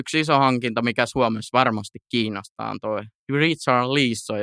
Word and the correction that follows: Richarlison: jos mä näen, vai Richard Leeson, vai miten se lausutaan. – Richarlison.